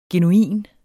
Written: genuin

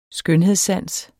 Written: skønhedssans

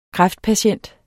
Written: kræftpatient

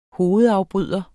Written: hovedafbryder

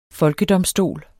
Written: folkedomstol

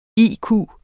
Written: IQ

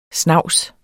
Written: snavset

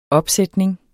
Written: opsætning

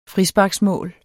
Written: frisparksmål

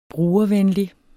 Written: brugervenlig

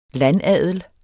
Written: landadel

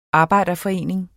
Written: arbejderforening

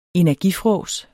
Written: energifrås

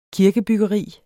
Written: kirkebyggeri

